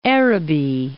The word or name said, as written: Araby